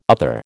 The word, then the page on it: other: American English